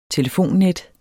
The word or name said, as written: telefonnet